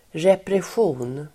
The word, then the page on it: repression: [represj'o:n]